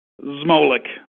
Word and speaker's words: Zmolek: Zmolek. – Zmolek. You got to get the Z, and it's kind of like I could never roll my R's in, in Spanish either.